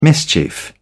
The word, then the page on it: mischief: British English